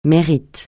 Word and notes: mérite: francais

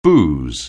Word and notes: booze: anglais

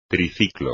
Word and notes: triciclo: espagnol

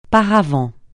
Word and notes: paravent: francais

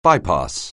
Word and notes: bypass: anglais

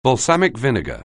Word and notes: balsamic vinegar: anglais

